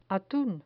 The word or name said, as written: atun